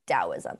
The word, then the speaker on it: Taoism: And at the end of the day, they're pronounced the same with the da-da sound. – The way Taoism. We would say it sounds more like a D, Taoism, yes.